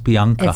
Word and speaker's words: Bianca: Because my name is Italian, spelt Bianca, but pronounced Bianca.